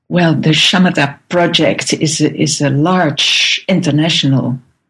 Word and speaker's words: Shamatha: And yes, am I saying that correctly? – Yes, the Shamatha Project. That's okay. indeed the way to say it.